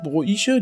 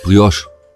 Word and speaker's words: Brioche: Wie spricht man das aus? Bräuche, Broiche, diese Brot, diese weiche... – Brioche. – Brioche. – Brioche. – Aber das ist doch Französisch, ne? – Das ist Französisch, oui.